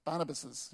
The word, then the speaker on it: Barnabas: Barnabases, yeah, that's how you say it.